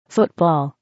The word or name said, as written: football